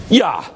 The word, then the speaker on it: ja: Ja. J-A, ja.